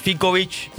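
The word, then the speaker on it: Ficovich: Juan Pablo Fikovic, ¿cómo se dice? Fikovic. – Bien.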